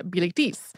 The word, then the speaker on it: Bilitis: in the French pronunciation Bilitis.